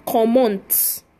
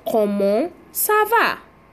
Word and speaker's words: comment: If we pick the word comment you will see that they didn't call it "comment." The letter T in common is silent. So we have it as what? Common sava. Common sava.